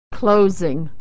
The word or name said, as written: closing